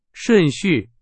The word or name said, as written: 顺序